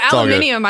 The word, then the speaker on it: aluminium: Oh, aluminium. I love when the Brits say it that way. How does an Irish person say aluminum? – We say aluminium as well. – You do? – Americans give a shit because it's like, it's spelled aluminum, but we put an I in it as well. We spell it aluminium as well as pronouncing it that way.